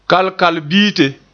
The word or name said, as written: kalkalbite